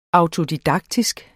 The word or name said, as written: auto-